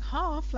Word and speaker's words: half: But if this LF comes after an A, like in half, then you don't pronounce the L.